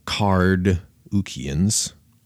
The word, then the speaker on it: Carduchians: And a lot of modern scholars think that these Carduchians, listen to that first syllable, Carduchians, a lot of modern scholars think that these are the ancestors of the Kurds.